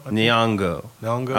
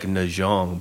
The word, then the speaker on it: Nyong'o: Nyong'o. I was saying like Najong, but I knew that was wrong. – I hope I'm right, but I'm pretty sure it's Nyong'o.